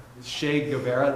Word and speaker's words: Guevara: Che Guevara, there we go.